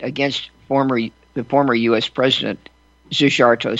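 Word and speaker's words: Szijjártó: Hungary's foreign minister, and forgive me for my effort at pronunciation, Zsigarto, S-Z-I-J-J-A-R-T-O, Zsigarto, said, "We stand with President Trump.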